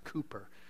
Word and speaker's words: Cowper: William Cooper, his last name is spelled C-O-W-P-E-R, but it's not pronounced Cowper, it's pronounced Cooper.